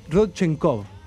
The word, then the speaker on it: Rodchenkov: Rodchenkov.